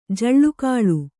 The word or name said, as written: ಜೋಲುಗಾಲು